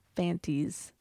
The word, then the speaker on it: Fantis: Then they discover that they have the evidence now that Krykus killed Tereus and not Fantis. That rhymes with panties.